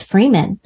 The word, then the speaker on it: Friedman: Have I been saying it wrong this whole time? Because I usually just say MJF, so maybe I just in my mind thought it was Friedman, but it's Freeman.